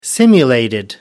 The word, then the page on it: simulated: British English